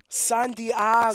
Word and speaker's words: San Diego: You got to say it like Ron Burgundy. – San Diego.